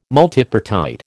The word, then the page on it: multipartite: American English